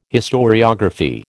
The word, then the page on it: historiography: American English